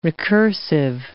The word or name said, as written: recursive